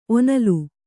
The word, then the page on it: ಒನಲಿ: onali